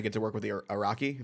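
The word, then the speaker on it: Arakko: Am I pronouncing that correctly? I've only read it. – Yes. People have different. That's generally the way I say the Araki, I know people who say the Araki. It's a fictional place. It's difficult to kind of say what the right pronunciation.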